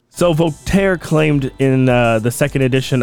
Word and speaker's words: Voltaire: I know I said that right. Voltaire. – Okay, I wasn't going to argue with you. – Fucking asshole. – In fact, Voltaire